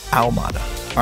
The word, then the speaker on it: Ahumada: That's my last name, Ahumada. And it's pronounced ow, like you got hurt, mada, Ahumada.